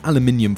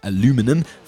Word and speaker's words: aluminium: In the episode today, you're going to learn why aluminium foil, and I'm going to be pronouncing it the British way for this episode, but that's the same as aluminum for those of you in the US,